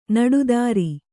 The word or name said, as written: ನಡುದಾರಿ